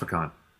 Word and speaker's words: pecan: but it's pronounced pecan. just is.